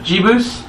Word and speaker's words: Jebus: J-E-B-U-S. Jebus? Jebus. Jebus.